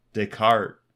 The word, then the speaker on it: Descartes: It's either Descartes or Descartes. – I think silent S's. – But I think, yeah, because it's French, Descartes.